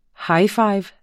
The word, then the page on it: highfive: ˈhɑjˌfɑjv; ˈhɑjˈfɑjv